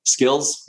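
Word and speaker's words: Skillz: Right now, like think something that I'm in, if you punch the symbols are SKLZ. I don't want to pronounce a company wrong. Sometimes I pronounce a company as its own ticker. It's Skills, mm-hmm.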